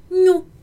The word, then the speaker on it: gnu: Esse gnu é aquele animal, inclusive, que tem nas savanas africanas, que no português vai se chamar Gnu, e no italiano vai chamar nu, ok?